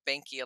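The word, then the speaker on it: Behnke: And so it's great to hear that there's still that active Facebook group for Banky alumni.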